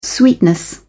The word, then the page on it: sweetness: British English